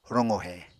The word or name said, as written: roqohe